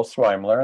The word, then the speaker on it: Sweimler: My name is Joel Swaimler, and that's how you pronounce my last name. It's a toughie.